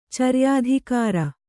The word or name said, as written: ಚರ್ಯಾಧಿಕಾರ